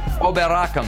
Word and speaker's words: Oberachern: Not preseason, the Cup in Germany, Joe Scally starts and scores for Gladbach, who won 9 1 Sunday over Oberachern. How'd I do on that pronunciation? – Uh, good.